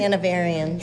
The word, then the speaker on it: Hanoverians: And I've already met a few people who are excited to see Rob, knowing that Rob and Lisa and Tammy are all Hanoverians. Did I say that right? – Hanoverians.